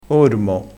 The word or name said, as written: ormo